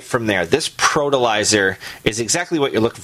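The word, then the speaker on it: Protilizer: The product's called Protolizer. – Spell it's it for me, please. – It's a P-R-O. – Okay. – T-I-L-I-Z-E-R. – Okay, fertilizer, better pro. – So it's Protolizer.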